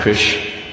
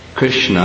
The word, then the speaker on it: Krishna: It's not Krish and Ram. It's Krishna and Rama.